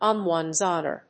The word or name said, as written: on one's honor